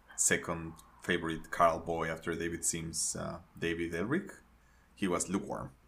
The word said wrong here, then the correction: Ehrlich: Did I say his name right? Not David. – David Ehrlich, yeah.